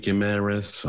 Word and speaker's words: Guimarães: Guimarães, I think he's pronounced.